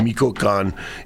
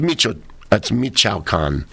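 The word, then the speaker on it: Michoacán: I'm sorry, I didn't say it right the first time. I said Michoacan. It's Michoacan.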